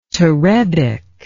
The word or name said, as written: terebic